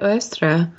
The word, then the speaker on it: Eostre: How do you pronounce the E-O-S-T-R-E spelling? Oestra? – I said Oestra.